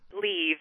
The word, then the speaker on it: Liv: Leave, L-I-V, as in Virginia. – Oh, like Leave Ullman. – You got it. – See, I would have said Liv. – How does she say her name? – It is Leave Ullman.